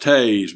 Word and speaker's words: Teays: It's pronounced Tays, but it's spelled TEAYS.